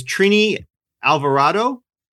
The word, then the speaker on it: Trini Alvarado: Dr. Linsky is Trini Alvarado.